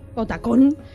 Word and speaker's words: Otacon: Otacon, o como se pronuncia. – Otacon, podrían haber tenido algo bonito.